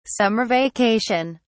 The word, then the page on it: summer vacation: British English